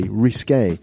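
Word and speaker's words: risqué: It's like risky, but sort of like pronounced as in the French way, risque.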